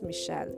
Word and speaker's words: Michel: it's Michel, it's a French psychologist, and I always have thought of him as a she because it's spelled like Michelle in English.